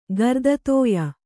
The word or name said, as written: ಗರ್ದತೋಯ